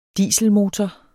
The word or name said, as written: dieselmotor